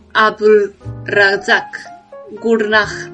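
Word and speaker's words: Abdulrazak Gurnah: Y otra vez, Haruki Murakami perdió el Nobel de la Literatura frente al tanzano Abul Razak Gurnah, no sé si lo he pronunciado bien yo lo doy por, por aprobado, pronunciación perfecta.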